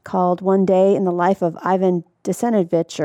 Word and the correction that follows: Denisovich: That's an Americanized pronunciation, but essentially Denisovich.